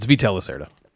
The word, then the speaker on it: Vital Lacerda: It's Vital Lacerda. I've heard so many people pronounce his name incorrectly.